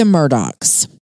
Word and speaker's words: Murdaugh: Well, first of all, if you notice, it's spelled M-U-R-D-A-U-G-H. And you're like, which is confusing. – You're saying that wrong, but it is pronounced Murdoch, despite the spelling.